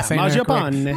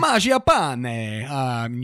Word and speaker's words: Mangiapane: One of my pickups last week was the Italian sniper, Mangiapane. Mangiapane. Is that right? – Yeah, Mangiapane. – Mangiapane. You have to say it like that or you're, he's not allowed to be on your team.